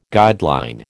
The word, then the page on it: guideline: American English